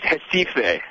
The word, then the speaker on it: Recife: All right, Recife. Is that what it was? Is that how it's pronounced? – Well, I think I'm going to break out a little Portuguese. Recife.